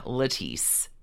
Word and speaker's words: Lettice: Lettice, spelled like lettuce.